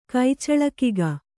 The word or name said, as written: ಕೈಚಳಕಿಗ